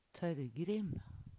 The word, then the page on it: Tørrgrim: Numedalsmål